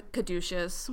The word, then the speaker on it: caduceus: and one of his symbols is the caduceus, which is That's how you pronounce that. I was like, she's about to say it. I don't know how to say it.